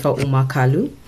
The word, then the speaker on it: Uma-Kalu: My name is Jennifer Umarkulu. You pronounce it as Umar. It's a U-M-A without an R.